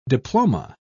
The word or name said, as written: diploma